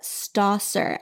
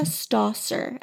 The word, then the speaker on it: Stawser: It's A Million Reasons Why, and it's by Jessica Strasser. S-T-A-W-S-E-R. She has... – Wait, say her name again? – Jessica Strasser. S-T-A-W-S-E-R.